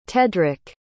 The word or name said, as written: tedrick